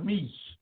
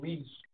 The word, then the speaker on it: Misch: His name is Tom Meech. I don't know if you know him. It's spelled Tom is T-O-M. And his last name, Meech, is M-I-S. C-H.